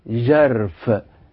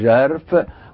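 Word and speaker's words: ژرف: يعني كلمة عميق في اللغة الفارسية بتنطق جرف، زاي عليها ثلاث نقطات، زاي عليها ثلاث نقطات، جا وبعدين الراء والفاء، فبنقول جرف، وجرف معناها عميق.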